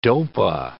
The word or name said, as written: dopa